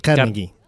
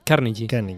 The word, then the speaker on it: Carnegie: ¿Y cómo se pronuncia? Porque meteré la pata de Carnegie. – Carnegie. – Carnegie. Carnegie, vale.